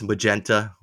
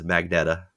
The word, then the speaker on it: magenta: First there was magenta, which I said as magneta.